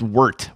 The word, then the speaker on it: wort: The wort is cooled and inoculated overnight in our Klopsch Chip loft. – It's pronounced wort.